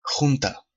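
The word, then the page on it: Junta: Spanish; Peru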